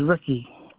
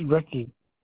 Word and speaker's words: Rickey: It's Ricky. R-I-C-K-E-Y. – I'm sorry, what, your name? I'm pronouncing your name wrong. – Yes, it's Ricky. – Mickey. – R-I-C-K-E.